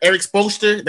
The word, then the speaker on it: Spoelstra: I would take Eric Spoelstra. That's how you say his name.